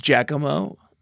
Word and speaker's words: Giacomo: But his name is Giacomo. – That's how you say it? Oh, sorry. You're the native Paldean speaker. So, yeah. – Yeah, sorry. It's Giacomo.